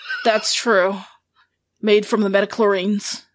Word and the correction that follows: midichlorians: I know I said that wrong. Wait, did I say it wrong again? – Midichlorians.